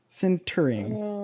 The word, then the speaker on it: sintering: Today, Form Labs is introducing a new selective laser Syntergen? Is that how you pronounce that? You're the science guy. Sintering. Syntergen. – Sintering? Sure.